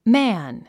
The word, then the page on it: man: mǽn; マァン